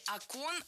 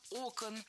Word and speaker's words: окон: Не окон, а окон. В единственном числе окно, окна, окну, окном – ударное окончание. А во множественном ударение переносим на первый слог. Окнами, окнах, окон.